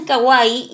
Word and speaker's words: kawaii: No, es que me puse a pensar y que la I la pronuncian como ay, entonces está bien, kawaii.